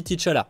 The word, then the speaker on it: T'Challa: Alors, euh, j'ai peut-être l'accent un peu éclaté, mais ça reste qu'on dit T'Challa normalement. Donc voilà, je précise parce que j'ai beaucoup de gens qui sont en mode Attends, mais c'est quoi cette histoire Faut, tu, sais même pas prononcer le mot euh, c'est le film, on dit T'Challa. Voilà, en VO, ça se dit comme ça.